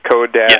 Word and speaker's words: co-reg: Now, you guys also do this co-reg deal. Am I saying that right? It's co-reg.